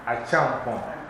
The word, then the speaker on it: Achampon: So the KY stands for C and H. So now pronounce it. Achampon. Because CH is pronounced ch. So that's Achampon. Okay, Achampon means you are blessed by God and you're going to have a lot of children.